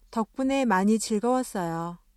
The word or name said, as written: 즐겁다